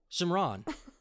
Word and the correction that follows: Simran: Simran. Why I keep saying Shim? Yeah, where did I get Shim from? – Yeah, I was like, what?